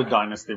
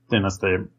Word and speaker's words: dynasty: And it's the 25th, the Nubian dynasty. I can't ever decide which way I'm going to pronounce it. Dynasty. – Dynasty, right. Okay. – Okay, let's go with dynasty for this episode at least.